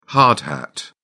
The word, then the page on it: hard hat: British English